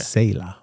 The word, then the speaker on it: Selah: S-E-L-A-H. – Okay. – Selah. Or Selah, depending upon, you know, who... what seminary you went to, you okay. – know, they pronounce it differently.